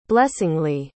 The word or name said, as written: blessingly